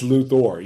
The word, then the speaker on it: Luthor: They it's spelled with a O-R on the end of it. And they really hit that enunciated O. Enunciated on the Luthor.